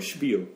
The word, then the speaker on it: Spiel: 그래서 H 없지만 발음을 할 때 H 들어가듯이 발음을 해요. Spiel.